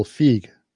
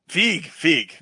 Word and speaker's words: Feig: It's one syllable, Feig. – Feig.